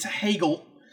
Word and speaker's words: Hegel: I'm sorry, I said it wrong. It's Hegel, German philosopher that Marx enjoyed.